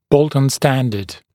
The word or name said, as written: Bolton standard